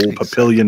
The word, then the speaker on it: Papillion: Old Papillion, Nebraska. Papillion. I don't know if that's really how you say it. Papillion. – Papillion. – Papillon. Yeah. Papillon. Le papillon. The butterfly.